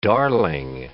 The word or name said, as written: darling